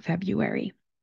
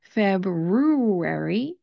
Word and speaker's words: February: The nice part about February, even though it has those two R's in the spelling, the first R does not get pronounced, especially if you're speaking with an American accent. Instead of pronouncing it February, that sounds very odd to me, we're going to pronounce it February. The first part, the first syllable that we're going to do is the F-E-B part of the word.